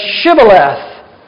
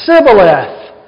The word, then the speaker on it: Shibboleth: The Gileadites tested to see if people were Ephraimites by making them say Shibboleth. But they couldn't say the H. They said Sibboleth. And if they couldn't pronounce it correctly, they were executed because it was known then that they were not truly Gileadites, but they were Ephraimites pretending to be Gileadites.